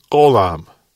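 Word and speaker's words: golam (עולם): It was the Hebrew word golam. We could spell that apostrophe O-L-A-M. The apostrophe standing for a Hebrew letter we don't have that basically means you have to make a choking noise in your throat.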